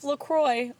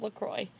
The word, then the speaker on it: LaCroix: Like they've is seen actually it, how you say it. La Croix.